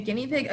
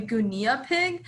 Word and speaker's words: guinea pig: that's not a gunia pig, it's a guinea pig, like that's not how you pronounce it.